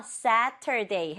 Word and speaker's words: Saturday: 어? 발음 좀 이상하죠? 천천히 읽으면 Saturday인데요. 이 t 소리가 단어 중간에 있으면 약간 흐리게 발음돼요. 약하게. 그래서 Saturday 해도 되고 Saturday 해도 돼요.